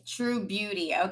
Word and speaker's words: Trubeauti: Trubeauti.